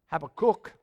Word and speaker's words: Habakkuk: Well, one of the famous prophets, Habakkuk, that's how they pronounce it in Israel, he said to the Lord, "How long, O Lord, must I call for help?"